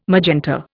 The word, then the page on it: magenta: Indian